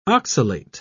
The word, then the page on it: oxalate: British English